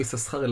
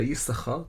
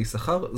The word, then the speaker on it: יששכר: לא יששכר אלא יששכר, כתוב יסס... שני סין אבל מבטאים פעם אחת, ההחלטה בסוף לבטא את זה יששכר, זבולון ודינה.